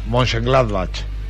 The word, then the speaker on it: Mönchengladbach: Mönchengladbach.